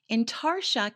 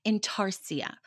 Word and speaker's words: Intarsia: Intarsia can also be pronounced Intarsia. Neither one is wrong, and it's spelled Intarsia.